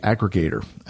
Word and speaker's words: Akregator: And then the last standalone client that I'll mention is the one for KDE, Aggregator. And that's spelled A-K-R-E-G-A-T-O-R. So it's almost spelled Aggregator with a K.